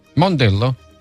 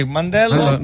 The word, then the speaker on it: Mandello: Mondello? – Mandello. – Non si dice Mondello. Ah, Lecco. – Ah, ma lì, Mandello.